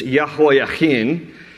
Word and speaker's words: Jehoiachin: Jehoiakim, it's a hard CH, it's Jehoiachin, and he's the son of a previous king, Jehoiakim, so very close in the pronunciation.